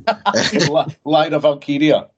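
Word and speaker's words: Lyra Valkyria: Laia Valkyra, the Irish bird, I can never pronounce her name. – Laia Valkyria, I believe the pronunciation is.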